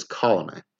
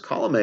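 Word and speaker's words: Colomé: But one of the things I can talk about that he told me was that Alex Colome, I got to get the pronunciation correct there. I believe it is Colome.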